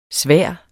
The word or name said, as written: svær